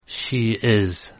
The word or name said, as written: she is